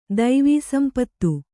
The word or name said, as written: ದೈವೀಸಂಪತ್ತು